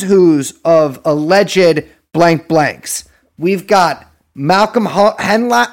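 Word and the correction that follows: Hoenlein: I don't know how to pronounce this fucking guy's name. – Honla. – No, you go through these assholes. You know all these motherfuckers.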